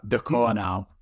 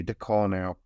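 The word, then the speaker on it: Ducournau: DeCornau. – Thank you, DeCornau.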